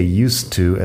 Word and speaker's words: used to: Used to, fíjate primero en la pronunciación, el verbo use en pasado sería used, pero este used to es como una frase hecha, no tiene que ver con el verbo de use y la pronunciación lo pronunciamos como si fuera una T en medio, lo juntamos used to o bien, si estás hablando un poco más rápido, sería como used to y justa con la vocal corta al final.